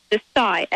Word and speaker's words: Xocai: Well, MXI Corp has brought us Desai chocolate, and that's pronounced Desai, X-O-C-A-I. It is the only chocolate in the world that's unprocessed.